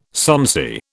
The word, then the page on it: sonsy: British English